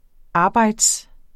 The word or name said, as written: arbejde